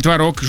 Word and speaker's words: творог: А вот, ну да, уж поясняй, творог или творог, жду. – А ты знаешь, выясняется, что оба варианта в нынешнем правописании они абсолютно равноценны.